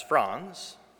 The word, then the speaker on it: Frans: It's spelled F-R-A-N-S, Franz.